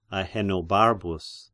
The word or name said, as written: Ahenobarbus